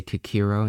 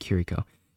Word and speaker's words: Kiriko: Earlier this week, too, Kiriko, I always say it wrong, I always say Kikiro instead of Kiriko.